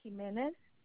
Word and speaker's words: Jimenez: Maria, M-A-R, I-A. And then Jimenez, J-I-M-E-N-E-Z.